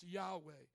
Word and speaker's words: Yahweh: You see it as capital L-O-R-D in the Old Testament. That is the continence, Y-H-W-H, pronounced Yahweh.